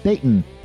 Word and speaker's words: Dayton: Note that I pronounced it like the locals here do, day glottal stop in Dayton.